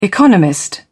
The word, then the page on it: economist: British English